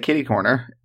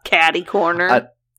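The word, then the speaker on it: catty-corner: In Seattle, they say kitty corner. And I'm like, kitty. – You're like, it's catty. – It's close enough that I got it. I'm like, oh, I understand what you're saying. You're just saying it wrong.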